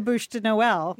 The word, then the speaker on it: Bûche de Noël: I'll just go buy the Bûche de Noël. – Yeah. – Am I pronouncing that correctly? – You got that. Spot on. Spot on.